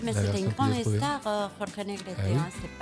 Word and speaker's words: Jorge: Tu l'as très très bien prononcé, ce que j'allais dire. C'est quand même très bien, tu as bien dit Jorge, chose qui n'est pas facile. – Ouais.